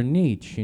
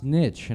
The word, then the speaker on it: niche: I was like, Your niche, you know, the one thing in life you're really good at. And he goes, It's pronounced niche. And I was like, No, it's niche.